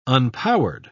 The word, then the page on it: unpowered: British English